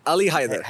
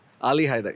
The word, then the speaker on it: Ali Haider: Ali Haider. – Haider? – Ali Haider.